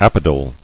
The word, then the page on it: Apodal: ap·o·dal